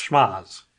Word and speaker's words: Schmohz: S-C-H-M-O-H-Z. – Schmods? – Schmoz? Schmooze? Schmoz? – Schmoz. It's got to be schmoz. – Schmoz. – They've got schmods. – You can't pronounce the C. It's got to be soft. Schmoz.